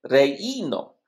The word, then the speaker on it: reino: En el reino. Ok. – Eso. En el reino. – Reino. En el. – Tú no estás pronunciando la I. Es reino.